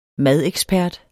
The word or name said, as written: madekspert